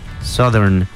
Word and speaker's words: Southern: Y noten cómo lo pronuncian él ahí, Southern, yep. No dice Southern. Southern, que pues, o sea, uno pensaría que si es South, entonces pues siguiendo, no sé, la cualquier lógica, ¿no? Lógica formal sería Southern, pero pues cuando, when English zigs, zig, it zags.